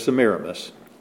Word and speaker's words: Semiramis: We used to always call her Semiramis, if you know her by that name. But the pronunciation is Semiramis.